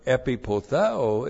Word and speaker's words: epipotheo: E-P-I-P-O-T-H-E long O. Epipotheo.